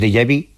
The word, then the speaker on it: déjà vu: lo que llamamos déjà vu, que eso, mira que bien lo he pronunciado. – Sí, muy bien, muy bien. Déjà vu, sí.